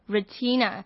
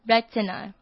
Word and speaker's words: retina: 那这个字非常非常非常多人念 retina，可能是因为看到它的字就会很想要这样发音，可是这个字正确的念法是 retina， retina，现在要开口跟着念 retina，因为它的重音是在前面一发 a 的音。